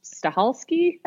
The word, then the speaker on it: Stahelski: Chad, whose name I can't pronounce, Stahelski. Is that right? – Yeah.